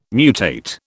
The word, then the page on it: mutate: British English